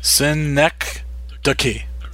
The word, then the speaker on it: synecdoche: Synecdoche. There we go. – Synecdoche, New York. Yes. – Yeah. I can say New York. – New York. Synecdoche, New York.